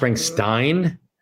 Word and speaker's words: Springsteen: I don't know if you've ever heard of Bruce Springsteen. – I think it's pronounced Springsteen.